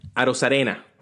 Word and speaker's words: Arozarena: Arozarena.